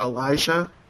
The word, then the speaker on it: Elijah: If you go to Yaspace, my name is spelled EZRAELIJAH, Ezra Elijah.